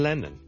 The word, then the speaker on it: Lennon: 第一個字呢就係、是、人名嚟嘅， John Lennon。 John Lennon， John Lennon 一個英國嘅好出名嘅歌手作曲家啦，Lennon， L-E-N-N-O-N，第一個係 L 音，所以唔好將佢讀成 N 音，唔係 Lennon，係 Lennon，Len，Len，Len，Len，Len，Len，Len，Len，Len，後面嗰個先係 N 音，所以 Lennon 仲要兩個 N 音添，Lennon 第一個 L，Len，Len，Len，Len。